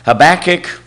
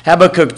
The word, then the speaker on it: Habakkuk: Habakkuk, depending on how you want to pronounce it. Habakkuk 2, verse 4 contains the phrase, "The just shall live by faith."